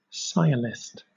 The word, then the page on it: sciolist: British English